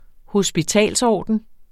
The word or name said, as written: hospitalsorden